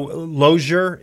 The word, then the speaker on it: Lozier: And so Lozier is how you pronounce the name? – Yes, sir.